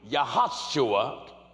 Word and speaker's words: Yahushua: Joshua, or as it's pronounced in Hebrew, Yahushua, English translation, Jesus.